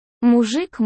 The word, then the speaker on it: мужик: Мужик мой, дорогой. С ударением на букву У.